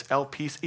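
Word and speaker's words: elpis (ἐλπίς): This word hope, it's elpis, E-L-P-I-S is the Greek word. It's pronounced elpis.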